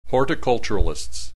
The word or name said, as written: horticulturalists